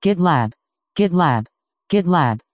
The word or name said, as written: gitlab